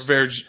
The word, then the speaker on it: verjus: Verjus. – Verjus. – Verjus. – Just drink it? – It is verju, pronounced verju, is the tart unfermented juice of unripe wine grapes with high acidity and a tart apple-like flavor.